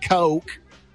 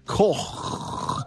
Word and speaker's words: Koch: I've been corrected by my German friend that that's the proper pronunciation. Not Koch, Koch.